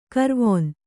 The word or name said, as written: ಕಾರವಾನ